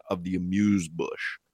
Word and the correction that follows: amuse-bouche: The second one you're killing. So I'm not even 100% positive. It's either amuse or amuse, and then it's bouche. Amuse or amuse-bouche. Amuse-bouche.